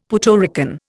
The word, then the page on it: Puerto Rican: Indian